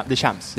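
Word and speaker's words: Deschamps: Porque viene destacando, viene haciendo un buen funcionamiento en el esquema táctico, estratégico del entrenador de Encheps. Corrígeme si lo estoy pronunciando mal. De Shams.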